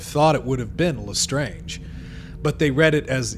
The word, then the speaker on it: Lestrange: I was thinking Bellatrix Lestrange. French. That's how I was pronouncing it in my head, was Lestrange. And then like the movies come out and it's Lestrange, and I'm like, I don't like that.